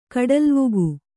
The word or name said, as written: ಕಡಲ್ವುಗು